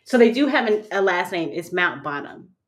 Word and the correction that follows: Mountbatten: Mountbatten, Mount Batten, Windsor.